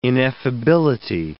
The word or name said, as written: ineffability